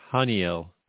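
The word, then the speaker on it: Haniel: Well, I believe it's pronounced Hanyel. – Ah, Hanyel. – Hanyel, yeah. – I've heard different ways of pronouncing it today. – Okay. – So whatever. Well, Hanyel, you're saying? – Yeah, well, because in Hebrew, the L ending has a glottal stop preceding it, meaning it's L, not yell.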